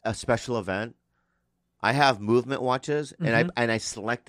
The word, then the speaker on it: MVMT: Movement! – Hey, what time is it? – MVMT time, baby. – MVMT time, baby. It's pronounced movement.